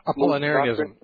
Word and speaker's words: Apollinarianism: the Apollinarianism? – Apollinarianism.